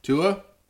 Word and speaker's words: Tua: Tua.